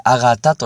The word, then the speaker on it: 縣: えー、この剣という字にもう一つ違う字を組み合わせて一つの文字として、えー、あがたと読むわけですね。